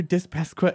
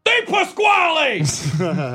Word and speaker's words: DiPasquale: She was like, "That's great, Mr. DiPasquale." And he just shouts at her, "DiPasquale! Moron!"